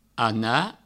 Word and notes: anar: ana

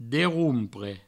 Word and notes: derrompre: Provençal; derroumpre

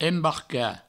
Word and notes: embarcar: embarca; Provençal

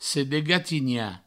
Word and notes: sedegatinhar: sedegatigna

